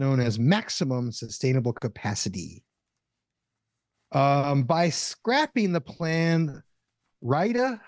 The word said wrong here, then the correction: Riyadh: how do you say that? – Which, what is it? That's the Saudi capital? – Riyadh.